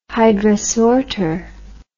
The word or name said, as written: hydrasorter